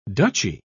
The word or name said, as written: duchy